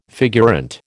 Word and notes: figurante: American English

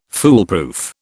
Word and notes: foolproof: British English

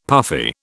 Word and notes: puffy: British English

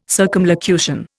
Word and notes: circumlocution: Indian

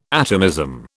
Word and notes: atomism: British English

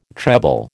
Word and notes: treble: American English

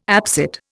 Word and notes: absit: Indian

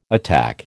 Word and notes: attack: American English

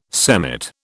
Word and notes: semmit: British English

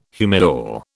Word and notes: humidor: British English

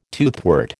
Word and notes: toothwort: American English

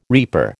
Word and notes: reaper: American English

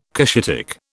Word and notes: Cushitic: British English

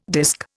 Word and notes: disk: Indian

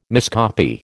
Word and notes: miscopy: American English

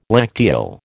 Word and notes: lacteal: American English

